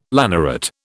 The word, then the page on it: lanneret: British English